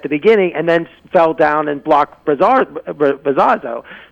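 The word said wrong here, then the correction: Bravazo: So I keep mispronouncing that name. – Bravazzo.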